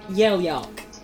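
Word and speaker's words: Yellyark: Y-E-L-L. – Y-E-L-L. – Y-A-R-K. – Yelyark.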